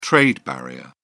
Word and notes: trade barrier: British English

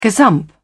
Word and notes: gazump: British English

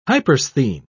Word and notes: hypersthene: British English

